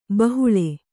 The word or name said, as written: ಬಹುಳೆ